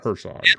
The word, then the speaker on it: Herzog: Herzog. – Werner. Like, he hasn't changed it. – Well, you have to say it like a German, right? – Yeah, you want to give him like a German pronunciation.